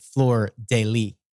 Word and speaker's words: fleur de lis: In French, you pronounce the S. Generally, American English, you would pronounce it fleur de lis.